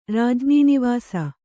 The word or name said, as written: ರಾಜ್ಞೀನಿವಾಸ